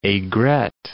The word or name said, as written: aigrette